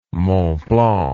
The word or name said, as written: Mont Blanc